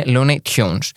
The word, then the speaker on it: Looney Tunes: Γιατί εγώ, όταν ήμουν μικρό, το έλεγα Πάμε να πάμε να δούμε τα Looney Tunes. Τα έλεγα έτσι. Ε, Παρ' όλα αυτά, εντάξει, η ζωστή προφορά προφανώ είναι Looney Tunes.